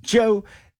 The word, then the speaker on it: Djo: It's spelled D-J-O, but it's pronounced Joe.